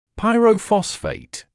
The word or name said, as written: pyrophosphate